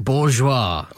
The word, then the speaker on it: bourgeois: Five words with hidden S words, silent S words. Isle. – Yes. – Island. – Yeah. – Debris. – Yeah. – Apropos. – Oh, nice. – And one I think you really like, bourgeois.